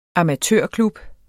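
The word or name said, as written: amatørklub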